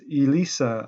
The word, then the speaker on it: Elisa: Throughout this whole episode, I've been mispronouncing her name when actually it is Elisa Harkins. And I'm so grateful to them for pointing this out. And this means that throughout this episode, we've had Eliza and Elisa. So three different pronunciations of the same spelling.